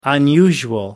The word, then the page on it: unusual: American English